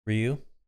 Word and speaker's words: Ryu: How do you pronounce it? Ryu? – Ryu.